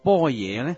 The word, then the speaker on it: 般若: 波野波罗蜜多六个字，如果用广州话直接读这几个字，就是般若波罗蜜多。但是为什么般若要读成波野呢？波野是印度梵文，正式的读音应该读做 Prajna。